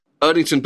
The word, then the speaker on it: Erdington: And I hope I'm pronouncing this right. You're from Erdington in the West Midlands? – Erdington, Birmingham, yeah.